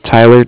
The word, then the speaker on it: Tyler: My name is Tyler, T-Y-L-E-R, and I'm with Bank of Cardiff. It's spelled C-A-R-D-I-F-F, as in Frank Frank.